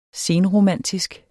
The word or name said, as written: senromantisk